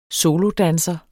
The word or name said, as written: solodanser